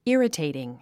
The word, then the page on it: irritating: írətèitiŋ; イラテイティン